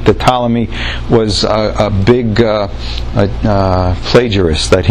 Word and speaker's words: Ptolemy: Ptolemy. That's not how you say it, so you say Ptolemy. But it's a PT.